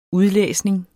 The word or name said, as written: udlæsning